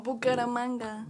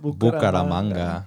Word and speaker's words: Bucaramanga: Oh, Bucaramanga. – Bucaramanga.